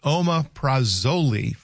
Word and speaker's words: omeprazole: It's pronounced omeprazole for heartburn.